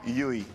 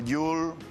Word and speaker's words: Llull: Por cierto, me llamo Llull. No Yul, Trul, Ful, Juli. Que no tengo ningún problema, ¿eh? Entiendo que cuesta de decir.